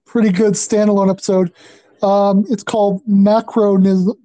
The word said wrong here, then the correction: Macrocosm: I can never pronounce that word correctly. – Macrocosm? – Macrocosm, that's it.